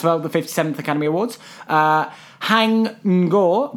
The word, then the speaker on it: Haing Ngor: Hang Ngo. – Hang Ngo. Okay, so he's 1985. H-A-I-N-G-N-G-O-R.